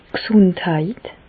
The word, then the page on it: d' G'sùndheit: Bas-Rhin; Reichshoffen